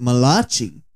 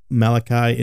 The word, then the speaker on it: Malachi: Some might pronounce it Malachi. But no. that is incorrect. It is Malachi.